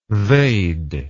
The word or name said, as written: they'd